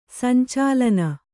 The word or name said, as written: ಸಂಚಾಲನ